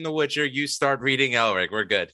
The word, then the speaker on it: Elric: I'm actually a fan of Michael Moorcock, especially the Eric of however it's pronounced, Elric, the albino owner of Stormbringer, the Black Blade.